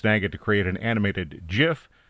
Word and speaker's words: GIF: And yes, I said GIF, even though it's spelled G-I-F. There's been a long-going debate over how to pronounce it. The person who created it says it's GIF. So, GIF it is.